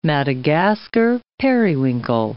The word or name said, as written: Madagascar periwinkle